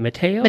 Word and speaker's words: Mateo: M-A-T-E-O. How do you say that? Mateo? – Mateo.